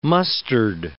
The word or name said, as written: mustard